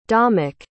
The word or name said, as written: domick